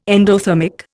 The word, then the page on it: endothermic: Indian